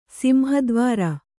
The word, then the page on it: ಸಿಂಹದ್ವಾರ: Kannada